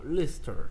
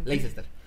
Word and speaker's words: Leicester: Eh, que, por cierto, vi cómo se pronuncia con el inglés británico y es como Leicester. Como que, ok, okay. Leicester.